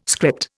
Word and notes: script: Indian

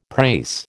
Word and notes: prase: American English

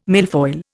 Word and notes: milfoil: Indian

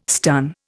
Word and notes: stun: Indian

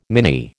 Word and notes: mini: American English

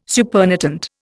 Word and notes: supernatant: Indian

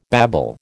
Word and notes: babble: American English

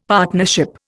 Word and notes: partnership: Indian

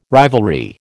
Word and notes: rivalry: American English

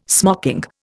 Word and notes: smocking: Indian

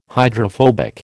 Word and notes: hydrophobic: American English

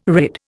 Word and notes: rid: Indian

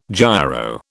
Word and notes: gyro: British English

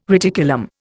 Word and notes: reticulum: Indian

